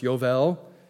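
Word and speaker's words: Yovel (יובל): Now, the word Jubilee itself is from the Hebrew word Yovel, or as it's pronounced, Yovel, which literally means a ram's horn, ram's horn trumpet.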